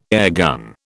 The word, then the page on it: airgun: British English